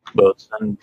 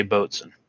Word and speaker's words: boatswain: And if Corley's listening, I did say boatswain. – Awesome. – He's a ex-Navy, retired Navy, and he corrected me when I was pronouncing it as a boatswain.